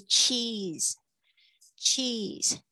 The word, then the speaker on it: cheese: 接下来是 cheese。 cheese，它有一个 e e 的发音，cheese，哦，这个像奶酪或者是 cheese 起司，都有人这样子去翻译它，cheese。那注意一下你们的 c h 的发音，有很多同学还是发吃的声音是错的，它是 cheese，cheese。好的，接下来是 onion rings，onion。